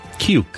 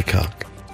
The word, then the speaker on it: cuck: Cuke. Like cucumber? – It's not how it's pronounced. – Say cuck.